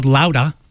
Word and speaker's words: Lauda: Any of you who are hyper-local know that that's Sal Baglio's new record, and it's called Lauda. As I always say, it's supposed to be pronounced in Bostonese, like that, L-O-U-D-A-H.